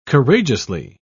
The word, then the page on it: courageously: British English